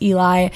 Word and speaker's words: Eli: If this is your first time listening, I don't know why you wouldn't know my name, but some people still don't know how to pronounce it, and that's their tea. Like, no shame. Like, if you want to call me Ellie, call me Ellie.